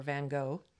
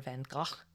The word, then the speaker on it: Van Gogh: Van Gogh or Van Gogh, as his name is really supposed to be pronounced, but that's another story, or a famous musician like Lin-Manuel Miranda.